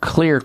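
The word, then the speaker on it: Xlear: Clear Corporation, the xylitol experts. Clear is spelled with an X, X-L-E-A-R. The X in that name stands for xylitol.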